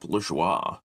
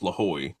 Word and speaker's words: Lajoie: Nap Lajoie or Nap Lahoy, depending on how you want to pronounce it.